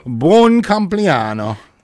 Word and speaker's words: compleanno: Buon compleanno.